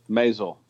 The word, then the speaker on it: Maisel: Maisel.